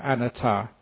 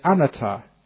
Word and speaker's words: anatta: You've got to be very careful, actually, when you pronounce the Buddhist term for non-self, anatta. Because I know that there was this Western monk who once, in England, he pronounced it anatta, or anatta. And he was talking to this English lady and said, you know, you're a nutter.